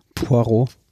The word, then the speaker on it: Poirot: Poirot.